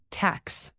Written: tax

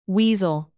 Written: weasel

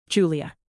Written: julia

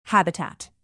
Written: habitat

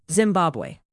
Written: zimbabwe

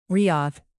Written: riyadh

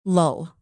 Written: lull

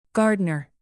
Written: gardner

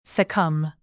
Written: succumb

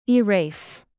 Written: erase

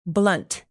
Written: blunt

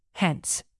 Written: hence

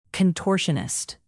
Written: contortionist

